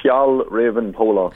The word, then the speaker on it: Fjällräven Polar: It's called the Fjällräven Polar.